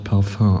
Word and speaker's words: Parfum: Im Französischen wird das Wort in der Tat Parfum ausgesprochen. Im Deutschen ist meines Wissens nach sowohl die Rechtschreibung mit U als auch die Variation mit Ü gängig. Das lässt natürlich darauf schließen, dass im Deutschen die korrekte Aussprache in der Tat Parfüm lautet.